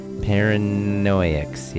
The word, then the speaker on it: Paranoiacs: I played Paranoiacs. Am I saying it right? It's hard to say that word. Paranoiacs. Yeah, that's it. Paranoiacs. Paranoiacs. Yes, by Chemtrails.